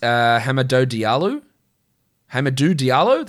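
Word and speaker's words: Hamidou Diallo: Maybe we also get Hamadou Diallo, that's a better pronunciation.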